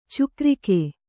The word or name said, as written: ಚಕ್ರಿಕ